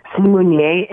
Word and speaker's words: Simunye: I couldn't pronounce the word Simunye. Is that how you say it? – Uh-huh, Simunye. – And what does it stand for again? – Simunye in Zulu means we are one.